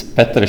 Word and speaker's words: Petr: Could you tell our millions of listeners the proper Czech pronunciation of your name? – It's Petr Špaček, but I don't care because I'm going to a lot of conferences, so, you know, I'm used to all sorts of pronunciations.